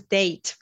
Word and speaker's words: Dejt: Name is Date, by the way. DEJT, like Serbian date. – The way how we pronounce it, this is how we write it. Yeah, date. – Exactly. – But with the J.